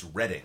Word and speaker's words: Reading: And so the answer is one across was poet's performance, reading, but if it's capitalized, it's reading, like the city in Pennsylvania.